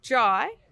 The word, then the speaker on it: Jai: Jai, we know it's Jai, like why? Like why, Jai? – Yep.